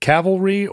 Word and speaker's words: cavalry: Is it cavalry or calvary? Which is the right one in this context? I never get it right. – What do you think it is? – Horses. Cavalry. – Yes. And you said it right. – And I think you always say it right and then you doubt yourself. – Yeah. – What you need is the courage of your convictions. – And Calvary is the biblical hill.